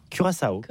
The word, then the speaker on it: Curaçao: Donc comment on prononce ça? – Curaçao. – Ah oui, Curaçao, j'aimais bien.